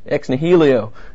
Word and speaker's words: ex nihilo: So first, God created the universe out of nothing, ex nihilo.